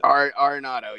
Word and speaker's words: Arenado: Nolan Arenado, is that how you say it? – Arenado, yeah, there you go. It's like cinnamon and aluminum.